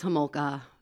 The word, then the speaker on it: Homolka: Or Homolka. It's Homolka. I messed up. – It's okay. – No, you know what? Her father is from Czechoslovakia, so the off might work. – But it is Homolka.